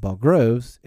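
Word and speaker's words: borogoves: And the first O in Bogroves is pronounced like the O in borrow. I have heard people try to give it the sound of the O in worry.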